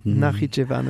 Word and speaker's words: Nakhitchevan: Donc nous sommes maintenant au Nakhitchevan, si je prononce bien, excusez-moi. – Oui, Nakhitchevan, oui.